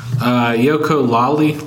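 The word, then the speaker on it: Yooka-Laylee: Yoko Lally, I don't even know how to pronounce it. – Like ukulele.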